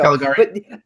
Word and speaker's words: Caligari: Caligari.